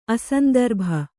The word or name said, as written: ಅಸಂದರ್ಭ